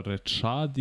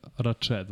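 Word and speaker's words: Rachaad: Ne znam kako se da... izgovara, Rečad ili Rached White? – Rašad. Rašad. Mislim, dobro, ja ga tako zovem, ne znam da li se tako izgovara. No, okej, može, ja se, okej, okay, Rašad White.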